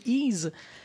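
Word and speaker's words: Ys: je relevais que le jeu s'appelait Ease, était prononcé Ease, mais je disais qu'on l'avait entendu dans Ease Origin.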